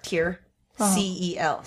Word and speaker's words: tiercel: Dad is called a Tiercel. T-I-E-R. Tier. C-E-L. Cell.